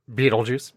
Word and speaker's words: Betelgeuse: Betelgeuse.